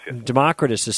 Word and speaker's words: Democritus: Democritus'